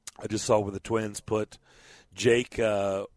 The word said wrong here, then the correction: Odorizzi: How do you say his last name? – Jake Odorizzi. – What is it? – Jake Odorizzi. – Odorizzi.